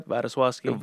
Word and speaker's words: Wladyslawski: Vladoslavsky.